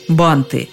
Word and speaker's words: банты: Как и в случае с лентой, с корня «банд» ударение никуда не уходит, как бы окончание не менялось. Ленты – банты, лентами – бантами. Ударение остается неподвижным.